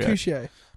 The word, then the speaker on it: Mercutio: I think there is because there is actually a character called Mercurio. – Mercutio. – Mercutio.